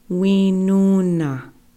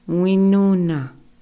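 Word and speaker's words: Wíŋuŋna: Okay, now for our females, the very first-born daughter, she was named Winuna, Winuna. Okay, let's pronounce that together. Winuna.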